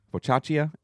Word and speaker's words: focaccia: And then is it pronounced focaccia? Focaccia? F-O-C-A-C-C-I-A? – I don't know.